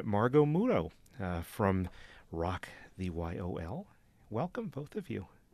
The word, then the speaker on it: YOL: pronounce it Yol or Y-O-L? – Y-O-L. – Y-O-L, okay.